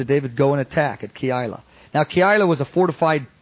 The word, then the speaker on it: Keilah: That's how you say it, Keilah.